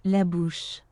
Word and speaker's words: La Bouche: La bouche.